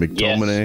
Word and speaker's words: McTominay: Well, McTominay, is that how you say his name? McTominay? – Yes.